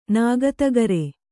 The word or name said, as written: ನಾಗತಗರೆ